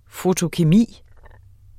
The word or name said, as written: fotokemi